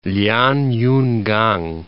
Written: Lianyungang

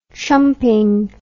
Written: chumping

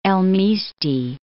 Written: Misti, El

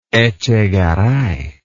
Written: echegaray

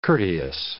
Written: courteous

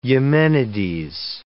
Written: Eumenides

